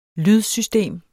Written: lydsystem

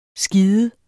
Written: skide

